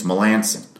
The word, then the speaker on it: Melancon: It's Melancon, by the way. M-E-L-A-N-C-O-N. How would you pronounce it? Melancon. But apparently, according to him, it's Melanson.